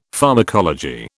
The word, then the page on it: pharmacology: British English